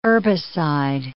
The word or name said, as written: herbicide